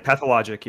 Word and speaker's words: Pathologic: Okay, so that's what I've been on, and then they're trying to get me on, I think, I don't know if I'm saying it right, Pathologic. Am I saying it right? – Pathologic, yeah.